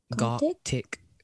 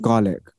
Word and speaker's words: Gartic: Gartic phone, yeah. – Okay, I thought it was a garlic. It's not garlic.